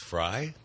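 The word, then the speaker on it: Frei: Yeah, I think we've already – And it's F-R-E-I. It's pronounced Fry? – Mm-hmm. Fry Brothers, yeah.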